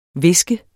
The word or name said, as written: viske